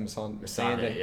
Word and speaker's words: Missandei: Is that Missandei? – Yeah.